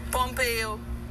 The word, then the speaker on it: Pompeo: Pompeo, who wrote the act that undid it, and now he's going to head your CIA.